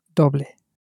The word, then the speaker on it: doble: Now, with that in mind, that it's spelt D-O-B-L-E, how do you think you would pronounce it in Spanish? How would double be pronounced? – Doble.